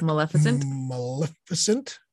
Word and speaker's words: Maleficent: We've got Maleficent.